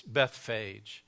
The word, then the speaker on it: Bethphage: And when they had approached Jerusalem and had come to Bethphage, to the Mount of Olives, let me help you with that word. You know the word Bethany, hear the accent on the first syllable, and this will help you from here on out forever to know how to pronounce this word. The other word would be Bethlehem, again, accent on the first syllable. If you pronounce the last part of this word, phage, which is a correct pronunciation, it's Bethphage. And so that's where they came to the Mount of Olives.